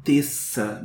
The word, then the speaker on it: Tessa: E ele fala um Tessa, né? Ele pronuncia mais o T e o, o S, né? Os dois S's que tem, e não Tessa como a pronúncia americana.